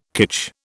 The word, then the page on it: kitsch: British English